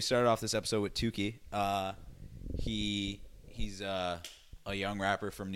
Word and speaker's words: Tookey: I'm going to pronounce it Tuki. I mean, two O's, right? It's like two, and then it's spelled T-O-O-K-E-Y. No, but look what I did, though. It's like a compound word. It's like T-O-O, and then K-E-Y, so Tuki. So like Tuki.